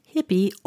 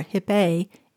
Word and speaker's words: XIPI: The precise origin of the words is unknown, though one theory suggests that slaves from Senegal used the word XIPI, pronounced hippie or hippe in the native Wolof language, which means to have your eyes open and be aware.